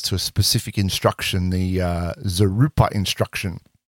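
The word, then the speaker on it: vzeroupper: Zarupa, the V is kind of silent-ish, yep.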